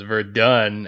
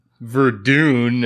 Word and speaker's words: Verdun: I don't know, but I don't know if you can relate to them that well, since it is actually pronounced Verdun and the Somme, not Verdun and the Somme or whatever the hell you just said.